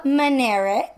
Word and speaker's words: Minarik: Okay, so that being said, Alyssa Minarik.